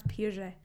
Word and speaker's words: Piaget: I'm also very impressed at how quickly you pronounce Piaget. Like, you just pronounce it very French, very good, and like very quickly.